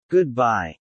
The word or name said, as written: Good-bye